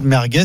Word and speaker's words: merguez: De débattre quand même, en l'écoutant, je me suis rendu compte, merguez, il y a encore une histoire de Z prononcé S comme dans Michelin.